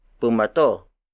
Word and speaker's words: bumato: If you add U-M in between the first two letters of bato, it becomes bumato. Bumato. Bumato. Bumato is spelled as B-U-M-A-T-O.